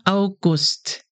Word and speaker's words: August: Dann kommt der August. You start with au, like in ouch. Au. Und dann Gust. August. The stress is on Gust.